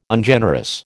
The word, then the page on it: Ungenerous: American English